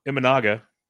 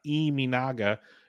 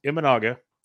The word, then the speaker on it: Imanaga: But Shoto Imanaga is how they pronounce it on TV, not Iminaga. It's Imanaga.